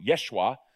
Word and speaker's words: Yeshua: And of course, the central figure of the New Testament and the Christian faith, Jesus, or to pronounce his name in Hebrew, Yeshua, was thoroughly, wholeheartedly Jewish.